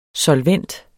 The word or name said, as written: solvent